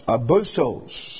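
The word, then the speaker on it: abusos (ἄβυσσος): The word for deep here in this verse, in the original, is a word that's pronounced abusos. And it is translated bottomless pit 5 times in the book of Revelation. And actually this word abusos, as you might detect, gives us the English word abyss.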